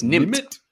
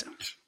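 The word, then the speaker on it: nimmt: I can't pronounce. – No, it's Nimpt. Six Nimpt. – Nimpt. Nimpt.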